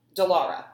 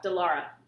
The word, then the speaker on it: Dallara: and I'm always going to butcher the name of this, Dallara. – Dallara. – Dallara. I always want to add an I.